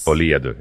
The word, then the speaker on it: Folie à Deux: And it looks to be a screenshot from the filming of the new Joker 2 Folie à Deux. – Is that how you say it? – Yes. – Folie à Deux, yeah.